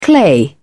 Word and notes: clay: British English

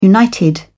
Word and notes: united: British English

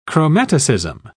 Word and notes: chromaticism: British English

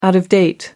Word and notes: out of date: American English

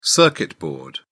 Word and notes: circuit board: British English

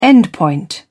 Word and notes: endpoint: British English

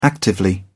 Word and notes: actively: British English

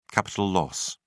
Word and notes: capital loss: British English

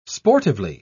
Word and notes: sportively: British English

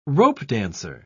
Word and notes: ropedancer: British English

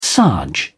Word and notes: sarge: British English

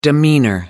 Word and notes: demeanor: British English

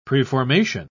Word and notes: preformation: British English

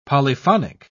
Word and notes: polyphonic: British English

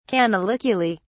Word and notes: canaliculi: British English

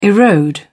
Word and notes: erode: British English

